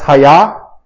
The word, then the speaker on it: haya: That's literally how it's pronounced, haya. It's haya.